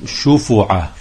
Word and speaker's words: شفعة: فصل في أحكام الشفعة، وهي بسكون الفاء، وبعض الفقهاء يضمها يعني شفعة.